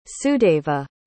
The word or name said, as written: sudeva